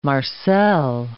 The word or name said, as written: marcel